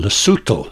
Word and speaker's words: Lesotho: Actually, it should be called Lesotho. That's how they pronounce it themselves, but it's spelt L-E-S-O-T-H-O.